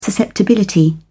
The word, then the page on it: susceptibility: British English